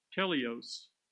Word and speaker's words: teleios: And it's pronounced teleios, T-E-L-E-I-O-S.